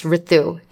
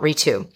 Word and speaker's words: Ritu: Actually, my name is pronounced Ritu and not Ritu.